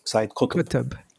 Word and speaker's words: Sayyid Qutb: How do you pronounce that? – Sayyid Qutb. Yeah.